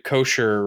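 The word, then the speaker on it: Koechner: David Kosher, right? That's how you pronounce his name.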